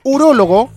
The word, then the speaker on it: urólogo: En tanto, un urólogo, también con acento en la primera O,